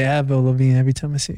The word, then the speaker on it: Avril: Every time I see her name, I want to say Advil. – I know. Exactly. you say it right. There's an R in there. You got to get that right.